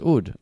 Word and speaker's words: oud: It's pronounced oud. I know that.